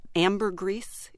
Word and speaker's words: ambergris: I read one, ambergris, is that yes. – How you pronounce it? – Yes.